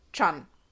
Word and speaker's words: Can: Sein Nachname wird ausgesprochen Chan. Can. Can.